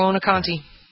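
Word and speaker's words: Buoniconti: The Hall of Famer, how do you pronounce it? Buonaconte, is that how you pronounce it? – Yeah, Buonaconte.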